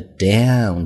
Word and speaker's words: down: 那么注意中间的 down 这样的一个单词的发音啊，美国人发的时候呢，鼻音会非常重，很夸张，中间是一个双元音 own。 down 这个鼻音很重要啊。